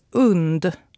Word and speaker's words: UND: I rapport på SVT sa de UND. – Mm. – Vi på radion har blivit informerade om att det ska uttalas UND. Tänk hund minus H.